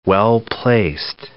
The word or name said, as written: well-placed